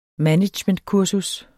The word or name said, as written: managementkursus